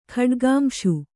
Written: ಖಡ್ಗಾಂಶು

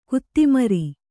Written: ಕೊತಮರಿ

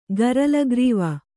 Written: ಗರಲಗ್ರೀವ

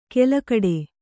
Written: ಕೆಲಕಡೆ

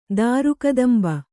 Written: ದಾರುಕದಂಬ

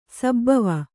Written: ಸಭವ

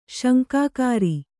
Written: ಶಂಕಾಕಾರಿ

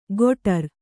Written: ಗೊಟರ್

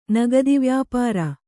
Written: ನಗದಿವ್ಯಾಪಾರ

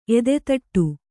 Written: ಎದೆತೊಟ್ಟು

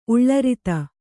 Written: ಉಳ್ಳರಿತ

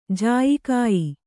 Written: ಜಾಯಿಕಾಯಿ

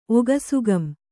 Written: ಒಗಸುಗಂ

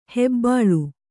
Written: ಹೆಬ್ಬುಲಿ

